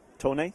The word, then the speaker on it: Tony: Tony. – Tony, can you spell that? – T-O-N-Y.